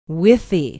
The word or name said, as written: withee